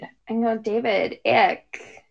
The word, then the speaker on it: Icke: And it's a conspiracy theory popularized by David Icke. Yeah, his last name is spelled I-C-K-E.